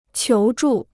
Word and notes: 求助: qiú zhù

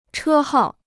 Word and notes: 车号: chē hào

